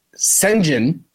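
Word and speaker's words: Şengün: I just think Isaiah Hartenstein is going to be able to kind of stop what Alperen Sengun, that's how you pronounce his name apparently. Ernie Johnson pointed that out during the broadcast.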